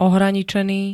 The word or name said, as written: ohraničený